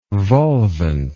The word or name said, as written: volvent